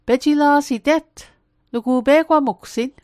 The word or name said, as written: pejila'sit